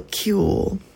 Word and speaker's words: Qewl: I started this third out of four mini-sets of my show with Cool by Socora Violetov, and it's spelled Q-E-W-L, so Cool.